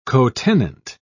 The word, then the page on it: cotenant: British English